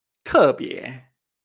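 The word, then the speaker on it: tebie (特别): Por ejemplo, otras palabras que tienen esta entonación es la palabra especial especial en chino se dice tebie.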